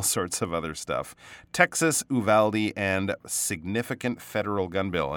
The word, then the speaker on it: Uvalde: Uvalde, I'm pretty sure is the way uh, we pronounce that that uh, town name in southern Texas.